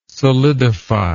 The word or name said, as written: solidify